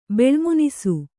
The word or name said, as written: ಬೆಳ್ಮುನಿಸು